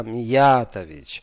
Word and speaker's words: Миятович: что в принципе правильное ударение Э, поставить на другой слог, что это не э, Миятович, не Миятович, а Миятович, что правильно говорит Дуня Миятович, что это типично для, для меня это югославская фамилия.